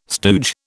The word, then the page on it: stooge: British English